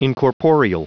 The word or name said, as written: incorporeal